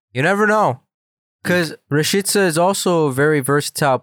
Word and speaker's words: Rashica: Oh my God, I'm butchering his name every time. Rashitska. – You're giving Yasin shit it's for karma, bro. – I don't know what happened. He just basically. Rashitza. Yeah, Rashitza. There we go.